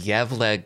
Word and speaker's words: Gävle: And 4 is the number of years in a row that the Yevle goat has survived.